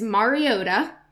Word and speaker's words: Mariota: Now, I want to say this right. I actually looked it up, and I played a video of saying his own name because I got so sick of saying it wrong. And I'm like, am I saying that wrong? It's Mariota. So Marcus Mariota on the Tennessee Titans.